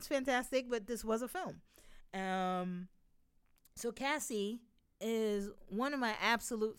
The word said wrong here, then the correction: Kasi: I said Cassie. Her name is Casey. Sorry. We're thrown off because it's spelled K A S I and it's pronounced Casey.